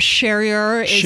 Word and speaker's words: Charrier: But Charrier is the butchered French that my people have taken on.